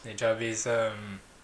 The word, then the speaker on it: Nujabes: How do you say Nujabes? – Nujabes.